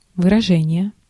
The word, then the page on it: выражение: /vɨrɐˈʐɛnʲ(ɪ)jə/